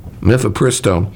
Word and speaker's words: mifepristone: mifepristone, which is an abortion drug.